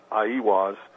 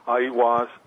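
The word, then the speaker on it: Aiwass: Well, I think the Book of the Law, which Crowley supposedly wrote through automatic writing, Crowley attributed to, I believe, the way it's pronounced is Aiwaz.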